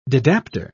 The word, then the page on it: didapper: British English